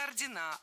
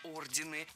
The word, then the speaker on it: ордены: Рыцарские ордена о рыцарских орденах. Это неверно. Если бы речь шла о знаках отличия, то было бы все в порядке. Но когда мы говорим об организациях, то ударение нужно сохранить на первом слоге, как и в начальной форме. Так что должны быть рыцарские ордены.